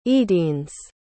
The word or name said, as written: eadignes